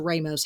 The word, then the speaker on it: Ramos: Is that Ramos or Ramos? How do you say it in Canada? – Uh, Ramos is how I would Ramos. say it. Okay, long A.